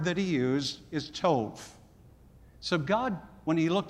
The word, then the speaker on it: tov: Now that word good is tov, T-O-V is what we would spell it in the English alphabet. Tov, T-O-V.